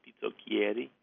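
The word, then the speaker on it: pizzoccheri: There is a buckwheat noodle in Italy called pizzocchieri. I think it's pronounced pizzocchieri, and it's from up north, where they make it with cabbage and fontina cheese.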